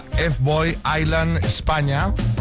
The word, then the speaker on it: F-Boy Island: porque se llama F-Boy Island España. ¿Lo he dicho bien, María? – Perfectamente.